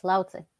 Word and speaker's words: Lao Tzu: Lao Tzu, that's how you say it, correct? – Yes, Lao Tzu.